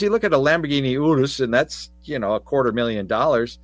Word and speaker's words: Urus: So this sits on the same platform as the Porsche Cayenne, the Bentley Bentayga, and the Lamborghini Urus, U-R-U-S, however you want to pronounce that. – Yeah, be careful. – Our Italian and Spanish-speaking friends perhaps could chime in on that. Urus is how I say it.